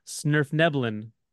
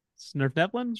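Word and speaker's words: svirfneblin: His path finally leads him near the, and I always, my co-host Mary will always tease me because I'll always mispronounce this, Snurfneblin. Snurfneblin? – Sort of Neblin, yeah. – There you go.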